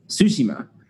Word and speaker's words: Tsushima: Yes, I was pronouncing it wrong. Tsushima.